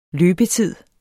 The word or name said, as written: løbetid